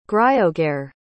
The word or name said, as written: griogair